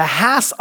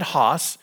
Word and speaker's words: Hass: A man by the name of Rudolph Hass, H-A-S-S, pronounced Hass like pass. All right, just so you know, if you've been pronouncing it Haas, you are incorrect. Because I've done my research and they're very, very particular. It is the Hass avocado, like pass.